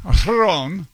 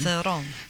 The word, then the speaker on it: Theron: Theron. At least I know how to pronounce her name. – Theron.